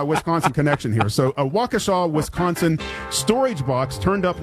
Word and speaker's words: Waukesha: It's called Waukesha.